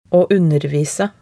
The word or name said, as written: å undervise